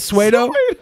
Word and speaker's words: pseudo: Pseudo. Yeah, that's how you pronounce it.